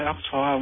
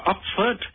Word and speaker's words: Oxford: Thế nó mới vỗ đầu vỗ trán, nó nói ồ cái này không phải Oxford mà cái này nó gọi là đọc là Oxford.